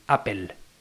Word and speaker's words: Apple: Pero bueno, sabemos que los norteamericanos tienen una forma exclusiva de pronunciar las cosas, pero lo correcto es decir Apple.